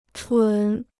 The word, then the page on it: 蠢: chǔn